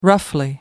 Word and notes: roughly: American English

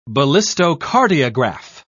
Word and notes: ballistocardiograph: British English

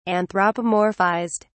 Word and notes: anthropomorphized: British English

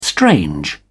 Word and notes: strange: British English

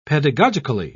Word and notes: pedagogically: British English

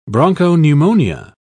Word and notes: bronchopneumonia: British English

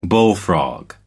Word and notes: bullfrog: American English

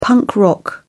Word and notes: punk rock: British English

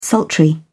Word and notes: sultry: British English

